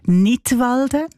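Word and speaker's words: Knidwalden: Unter einem Titel mit einem Wortspiel Nidwalden, ausgesprochen wie der Kanton Nidwalden, aber geschrieben mit einem K vorne dran, so wie beim englischen Wort für Lismen, to knit.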